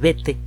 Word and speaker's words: Bethe: Eso se debe en buena medida a un eh, físico alemán que tuvo que huir de la Alemania nazi, Hans Bethe. Se escribe B-E-T-H-E.